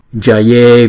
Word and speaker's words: jaye: Gamane gare guru guru guruni guni chale achale muchale jaye vijaye. Chỗ này chúng ta nên để ý nè. Chữ C chúng ta đọc là cha cha. Còn chữ J chúng ta đọc là ja ja.